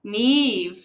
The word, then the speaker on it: Niamh: Okay, so name number two is spelled N-I-A-M-H. – Very common. – Neva? – Neve. So you were close. – I'm getting the pattern down. Yeah. The M-H-E is like a V sound, right? Okay. So the next name is spelled A-I-S-L-I-N-G.